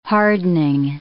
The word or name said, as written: hardening